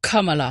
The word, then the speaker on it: Kamala: Ikke noe sånt man skal tenke komma og så la. – Kamala. – Kamala. – Ja. – Ja, ikke Kamala eller Kamala eller... – Ja. – Eller, nei, jeg vet ikke. – Kamala. – Kamala. – Ja, det liksom trykk på det første der.